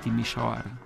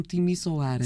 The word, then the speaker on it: Timișoara: Muitas vezes diz em Portugal, Portugal como Timisoara. – Sim, diz-se muitas vezes Timisoara. – de facto, não se escreve com H. – Sim, porque o que acontece é que por baixo do S existe uma espécie de uma cedilha que nós não temos no nosso.